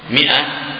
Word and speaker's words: مئة: مائة سنة، مائة سنة، لا الألف ما تنطق حضرتك الله ترسب ولا تنطق. نعم مئتي، لا مئتي سنة، هو قد ترسم ماء لكن هي ما تنطق. مئة أحيانا أظن تجعل فيها ألف لكنها لا تقرأ.